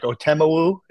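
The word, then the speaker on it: Otomewo: But like Otemowu, is that how you say his name? – Yeah.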